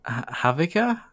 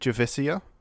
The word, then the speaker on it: Javicia: I was thinking more like Havika? Havish? I don't know. – I don't know, because I was watching a video by a YouTuber, for those of you who care, Boba Talks. He does a lot of comic book stuff on YouTube. And I think he pronounced it Javicia.